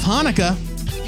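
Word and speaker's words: Hanuka: Next month, we'll be reviewing The Realist from Boom Studios, written and illustrated by Israeli cartoonist Asaf Hanukkah. That is how you pronounce it, according to the comic. – It's like Hanukkah Harry.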